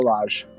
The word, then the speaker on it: Lage: but is it Largo? – Bruno Lage. – Bruno Lage, thank you for the pronunciation and making me out like a right Dale boy.